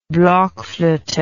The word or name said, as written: blockflote